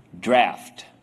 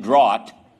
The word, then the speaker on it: draught: "draft." All right, I'll explain that in a little bit. But that's how you say the word. You say, it says draught. I know, but it's pronounced draft.